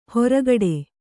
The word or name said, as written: ಹೊರಕಡೆ